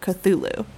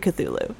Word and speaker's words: Cthulhu: Or is it Cthulhu? – The characters in this kind of big Cthulhu mythos, we can't pronounce them correctly. Like, I say Cthulhu, and anybody who's going to write in and correct me is wrong, because Lovecraft is pretty clear you can't pronounce those names because we don't have tentacles and 30 tongues. And there's, like, Cthulhu, there's Cthulhu. The CTH can be just, like, Cthulhu.